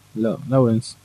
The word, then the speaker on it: Laurence: ¿No es Lawrence? ¿No se dice Lawrence? – Lawrence.